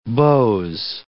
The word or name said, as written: beaux